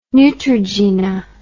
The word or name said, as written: neutrogena